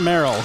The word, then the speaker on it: Marihyl: M-A-R-I-H-Y-L is pronounced Merrill.